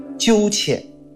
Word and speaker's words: 鸠浅: 这 2个字读作鸠浅，鸠就是斑鸠的鸠，浅就是深浅的浅。